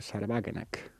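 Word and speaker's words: Sahra Wagenknecht: es el de Sarwagenek, o como claro, se diga en alemán, ¿no? – Está bien pronunciado, ¿eh? – Sí.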